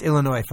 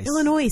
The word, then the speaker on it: Illinois: It's Illinois, fucking. Don't ever say Illinois. – Illinois. – You sound like an idiot. It's Illinois. – I know it's Illinois. – I know the S is silent.